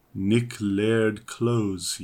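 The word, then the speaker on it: Nick Laird-Clowes: Original music by Nick Lard Clowers. – Wow. – Is that how you pronounce his name? – Nick Laird Clowes.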